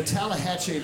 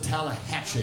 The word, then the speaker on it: Tallahatchie: It has those four syllables. Yeah, the four syllable Tallahatchie.